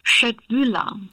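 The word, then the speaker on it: köttbullar: Nochmal, wie werden die ausgesprochen? – Köttbühler. – Gut, Lektion 1 in Schwedisch, probieren Sie es doch nachher mal bei Ihrer Bestellung.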